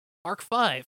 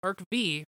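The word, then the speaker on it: Arc-V: So, anyways, after Zexal, we move on to Arc 5, which I mistakenly pronounced as Arc V for many, many long times.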